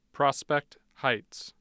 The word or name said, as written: Prospect Heights